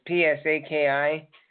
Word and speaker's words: Psaki: Former Vice President Joe Biden's press secretary, Jen Psaki, I think that's how you pronounce his name, it's Psaki, warns the media of Russian propaganda on Tuesday after images of her wearing a pink hammer and sickle hat resurfaced on social media.